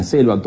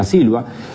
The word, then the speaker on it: silva: Y termina, hace un juego con selva y silva, que pronunciado en portugués suena muy parecido: todos somos de la selva, todos somos de la silva.